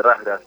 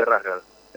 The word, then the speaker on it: Razgrad: Está en, a ver, vos corregime, en el Ludogorets de Razgrad, de la Liga de Bulgaria. – De Razgrad, sí. – ¿Está bien así? – Ludogorets de Razgrad. – Lo pronuncié más o menos.